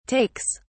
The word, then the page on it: takes: British English